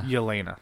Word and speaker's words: Yelena: Yelena, thank you.